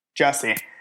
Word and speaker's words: Jussie: Jussie.